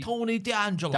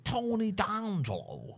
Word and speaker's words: Tony D'Angelo: Tony D'Angelo. – D'Angelo. Sorry, pronouncing. – Tony D'Angelo. – Tony D'Angelo.